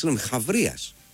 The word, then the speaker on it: Χαβρίας: Τσάμπριας. Χάμπριας, δεν ξέρω πώς ακριβώς διαβάζεται αυτό και τι είναι ακριβώς. Είναι, Χαβρίας. Αθηναίος στρατηγός του 4ου αιώνα. – Χαβρίας. Πολύ Χαβρίας, λοιπόν.